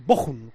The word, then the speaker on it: Bochum: Y siempre me ha dicho que es el Bochum, es el Bochum, como si pronuncias una J, Bochum.